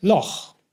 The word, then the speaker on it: Loch: Und Loch wird in der Tat mit CH ausgesprochen.